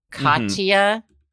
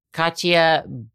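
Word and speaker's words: Khatia: Katia, spelled with an H, K-H-A-T-I-A. Katia Buniashvili.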